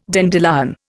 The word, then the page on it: dandelion: Indian